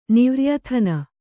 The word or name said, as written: ನಿವ್ರ್ಯಥನ